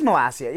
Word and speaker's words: Malacia: It is Malacia. Yes. For whatever reason, somebody decided not to tell the Europa League commentators the entire match. Malacia, Malacca, literally anything but Malacia.